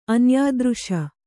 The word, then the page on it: ಅನ್ಯಾದೃಶ: Kannada